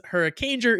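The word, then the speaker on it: Hurricaneger: Kieran says, Hurricanger is just hurricane plus jur, as in ranger, not whatever the hell you said it was.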